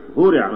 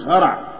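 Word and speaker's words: هُرِع: أشهرها طبعا فعل مشهور جدا هورع، هورع ما فيش هرع، هورع يهرعون، يو مش يهرعون يهرعون، فعل هورع ده فعل مبني المجهول.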